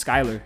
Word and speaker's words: Skyler: And my parents told you, yeah, his name's Skyler, S-K-Y-L-E-R.